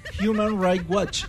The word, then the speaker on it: Human Rights Watch: Human Rights Watch.